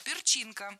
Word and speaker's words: перчинка: Сразу приходит на ум Spice Girls, да, Перченки, вот все эти. Ну, я себя поймала на мысль, что мне ни одной песни их не нравится. – Перченка, неверно. Правильный вариант – перчинка, с ударением на втором слоге.